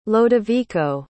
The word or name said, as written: lodovico